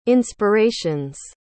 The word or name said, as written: inspirations